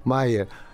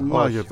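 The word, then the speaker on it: Majer: Θέλω κάτι να σε ρωτήσω, γιατί εγώ έχω αποκτήσει μία αδυναμία στον Λόβρο Μάιερ. Μάιερ ή Μάγερ, παρεπιπτόντως. Προφέρεται το γάμα. – Μάγερ, τον λέμε.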